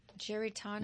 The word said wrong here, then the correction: Garitano: No, Garitano.